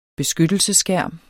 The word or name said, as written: beskyttelsesskærm